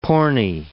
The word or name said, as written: porny